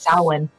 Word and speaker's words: Samhain: it looks like it's spelled Samhain, but it's pronounced Samhain.